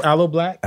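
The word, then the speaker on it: Aloe Blacc: Aloe Black.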